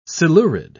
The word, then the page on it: silurid: British English